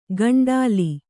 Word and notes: ಕಂಟಲಿ: kaṇṭali

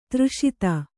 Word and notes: ದುರಾಸದ: durāsada

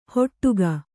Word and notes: ಹೊಟ್ಟುಗ: hoṭṭuga

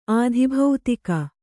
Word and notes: ಆಧಿಭೌತಿಕ: Kannada

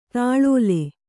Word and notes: ತಾಳೋಲೆ: tāḷōle

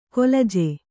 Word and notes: ಕೊಲಜೆ: kolaje

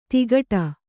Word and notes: ತಿಕಟ: tikaṭa

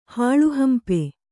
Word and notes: ಹಾಳುಹಂಪೆ: hāḷu hampe

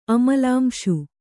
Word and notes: ಅಮಲಾಂಶು: Kannada

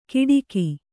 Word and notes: ಕಿಡಿಕಿ: kiḍiki

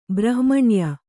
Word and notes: ಬ್ರಹ್ಮಣ್ಯ: brahmaṇya

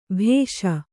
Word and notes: ಭೇಷ್: bhēṣ

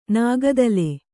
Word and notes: ನಾಗದಾಳ: nāga dāḷa

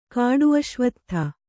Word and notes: ಕಾಡುಅಶ್ವತ್ಥ: kāḍu aśvattha

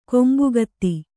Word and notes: ಕೊಂಬುಗತ್ತಿ: Kannada

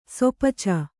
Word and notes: ಸೊಪಚ: Kannada

